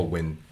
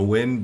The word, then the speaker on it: Nguyen: It's well, I have friends in London who are called Nguyen, but it's not pronounced, and I and they let me say Nguyen. That's so wrong. And it is pronounced Nguyen. Nguyen. – Oh, Win. – Oh, just when? – Yeah, yeah. Like, but like the, it's so soft. It's not no win, but like win.